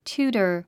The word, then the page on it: tutor: t j úːtə r; チューター